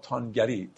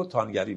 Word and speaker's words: توانگری: نه زمین را زان فروغ و لمتری یا لمتری، نه خداوند زمین را تانگری. توانستن دوستان خیلی زیاد در مثنوی تانستن تلفظ میشه، صیغه های مختلفش و مشتقات مختلفش هم واوش تلفظ نمیشه، اینجا هم توانگری رو تانگری میخونیم.